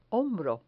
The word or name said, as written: hombro